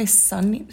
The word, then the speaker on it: Sunnei: So for those who haven't seen it, how do you pronounce it? Is it Sunny? – It's spelled S-U-N-N-E-I. Yeah. I would say Sunny.